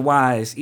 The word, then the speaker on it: Weisz: Rachel Weisz, easy on the eyes. That's how I remember that. – Yes. – Not that she's beautiful, but that her name rhymes with eyes.